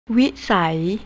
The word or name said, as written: wisai